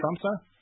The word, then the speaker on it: Tromsø: And then Arnie Henrik Tondersen in Tromsø, Norway. I'm probably pronouncing that wrong. Tromsø? Is that right? Tromsø? – Yeah, it's okay.